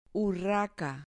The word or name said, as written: urraca